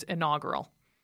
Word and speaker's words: inaugural: Oh, because I'm trying to inaugural. – You're trying to put a whole extra syllable in there, babe. It's inaugural.